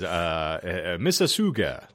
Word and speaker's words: Mississauga: I just can't pronounce it properly, but yeah, it says Mississauga, like Mississippi, but with an ooga.